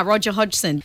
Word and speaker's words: Hodgson: Roger Hodgson. Am I saying that right? – Yeah, you are. Roger Hodgson.